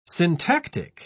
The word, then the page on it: syntactic: British English